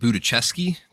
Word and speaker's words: Budziszewski: We have a guest host tonight, Nate Budziszewski.